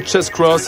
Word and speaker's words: Chase Cross: Je rappelle son nom, Chase Cross. Ça s'écrit C-H-A-S-E, plus loin, C-R-O-2-S. Voilà pour la prononciation.